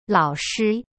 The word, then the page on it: 老师: lao-shi